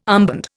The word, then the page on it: armband: Indian